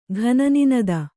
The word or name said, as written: ಘನನಿನದ